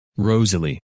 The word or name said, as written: Rosalie